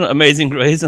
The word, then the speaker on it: Amazing Grace: Oder habe ich was falsch ausgesprochen? – Nee, schon Amazing Grace und das Alle meine Lebe Tag, passt schon.